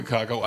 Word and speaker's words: Kakao: Der Kakao, also Kakao. Ja, ja, sagen ja, der Badener sagt Kakao. – Der sagt Kakao. Kakao. Mit der Betonung, ganz wichtig.